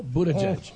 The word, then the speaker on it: Buttigieg: Well, did I say his name right, or is it Buttigieg? – No, Buttigieg. – Oh. Yep. Judge-a-butt? Yep. Backwards? – Judge-a-butt, yeah.